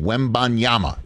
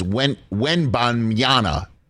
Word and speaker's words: Wembanyama: That's how you pronounce his name, Wembanyama. – I think the bon is what. – It's the bon. It's the well, again, because you think that could be the M, or it's Wembanyama.